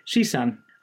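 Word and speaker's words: Shisan (十三): And when pronounced in Mandarin, 13 sounds like Shisan, and that means definitely vibrant.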